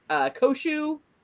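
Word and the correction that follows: Khonshu: Kanshu, yeah.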